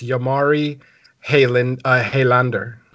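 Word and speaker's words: Jalmari Helander: So it's Jamari Haylander.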